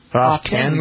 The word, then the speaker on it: often: Often. – Where did often come from? – I don't know. Throw a T in there. – Often. But the correct pronunciation is often. – Often. – Often. A-W-F-E-N.